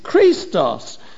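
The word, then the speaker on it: Christos (χρηστός): It's almost like Christ, which is Christos, C-H-R-I-S-T-O-S. That's where we get Christ from. It's just a Greek word, Christos. This word good is Christos, C-H-R-E-E-S-T-O-S, as you pronounce it, Christos.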